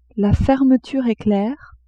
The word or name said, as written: fermeture eclair